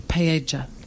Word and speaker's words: paella: Paella. That's how they pronounce it. – Correct. – There. Wow. Okay. We have, because you've got those two L's. – Yeah. – And when you get those two L's together, it's a different letter, yeah.